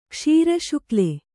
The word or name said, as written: ಕ್ಷೀರಶುಕ್ಲೆ